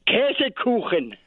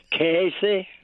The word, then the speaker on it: Käsekuchen: Käsekuchen. – Hold on a second. Let me try it. Käsekuchen. – No. – Oh. – Käse. – Käse. – Kuchen. – Kuchen.